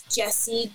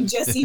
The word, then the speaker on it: Jessi: My username is Jesse Jordan Star. Jesse is spelled J-E-S-S-I, no extra E, so Jesse Jordan Star.